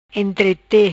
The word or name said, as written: Entrete